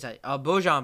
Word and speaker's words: Bojan: I think it's Bojan Bogdanovic. That's how you pronounce his name.